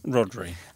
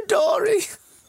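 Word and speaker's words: Rhodri: I thought his name was Rodori. I just read it as Rodori. Anyway, actually, it's Rodri.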